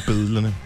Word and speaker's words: billederne: Jeg elsker, elsker unge mennesker, der er opvokset øh, i hovedstadsområdet, som øh, udtaler B-I-L-L-E-D-E-R for bedlerne.